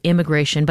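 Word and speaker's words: I'mmigration: One is called I'm Migration, so immigration, but I'm in the front. It is pronounced immigration.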